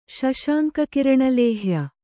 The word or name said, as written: ಶಶಾಂಕಕಿರಣಲೇಹ್ಯ